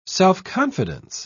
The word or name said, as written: self-confidence